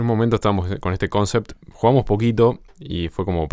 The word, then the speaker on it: Concept: Claro, Concept se llama, y ese lo pronuncié mal. Concept se Concept.